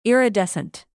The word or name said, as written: iridescent